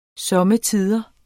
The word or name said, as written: somme